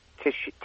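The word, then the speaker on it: Tischi: It's T-I-S-C-H-I. – Tishki. – And then Tishi, it's pronounced. And then Toshi, T-O-S-H-I.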